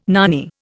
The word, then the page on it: nanny: Indian